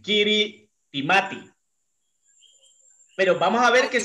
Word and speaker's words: Kiritimati: La isla de Kirimi, Kirimita, Kirimi, se escribe Kiritimati.